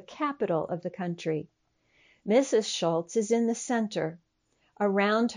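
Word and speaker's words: Šolc: Their name is spelled S-O-L-C, and you would think it would be pronounced Solk, but it is a Czech name, and so you say Schultz.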